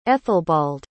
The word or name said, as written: ethelbald